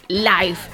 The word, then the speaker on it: Live: No, pero contesta live, antes, live, que aún no te he presentado. – Live. Blai Live Comedy Show. – Blai es el cómico valenciano que regresa con su nuevo espectáculo, Blai Live Comedy Show.